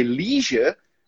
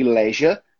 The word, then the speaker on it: leisure: Porque leisure é a pronúncia americana da palavra. E leisure é a pronúncia britânica. As duas estão corretas.